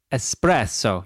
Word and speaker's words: espresso: In fact, I actually looked it up once and it's espresso. You actually put like a lot of emphasis on the double S.